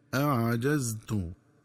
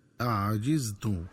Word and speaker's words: أعجزت: وكذلك ينبغي التنبه للنطق الصحيح للفظ أعجزت، فيقرأ بفتح الجيم هكذا أعجزت، ومن الخطأ قراءته بكسر الجيم هكذا أعجزت.